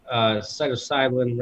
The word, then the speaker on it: psilocybin: MDMA, psilocybin, right? How do you say that? – Psilocybin, yeah.